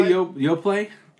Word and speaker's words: Yoplait: Did you say Yoplait? – Yeah. – Do you not pronounce the T? – The T is silent.